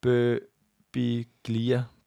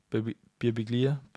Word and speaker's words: Birbiglia: B-I-R-B-I-G-L-I-A. Bøbiglia. Bøbiglia. Bøbiglia. – Mike Bøbiglia. – Bøbiglia. – Det er ham, tror jeg, folk finder. Bøbiglia.